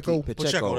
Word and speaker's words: Pacheco: I always forget how to say it. You know what I'm saying? – Pacheco. – There you go.